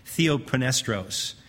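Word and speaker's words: Theophanestros (θεόπνευστος): Theophanestros. The P is silent. Theophanestros.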